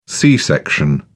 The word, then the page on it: c-section: British English